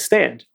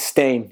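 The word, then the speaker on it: Stane: Stain or Stan? – I think it's Stain.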